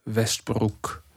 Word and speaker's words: Westbroek: Ich glaube, OE spricht man U aus. Westbroek also der, der Song heißt Jack the Ripper und ich zitiere mal jetzt ein paar Stellen.